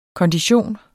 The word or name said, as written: kondition